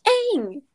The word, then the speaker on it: Aang: Is that how you pronounce it in that? Aang? – Yeah. It's Aang. You absolutely... – It's pronounced Aang.